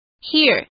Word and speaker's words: hear: Hear 听见，当 EA 这个字加上 R 时，我们只需要念短音的 it 就可以了，像是耳朵 ear，听见 hear。